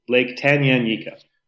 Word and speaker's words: Tanganyika: Lake Tanganyika.